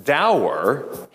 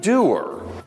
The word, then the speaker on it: dour: He's not dour, he's doer.